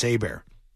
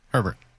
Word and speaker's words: Herbert: Like, these poor kids coming out, these young kids, whether it's Tua, whether it's him, whether it's Hebert, Herbert, or whatever, however the hell you pronounce his name. – Herbert. – Herbert. Yeah. That guy.